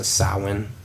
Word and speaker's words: Samhain: Like Yule and Halloween, which is known as Samhain, that is spelled Samhain. But when you put M and H together based on the original language that would have spoken it, that creates a W, so it's a Samhain.